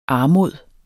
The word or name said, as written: armods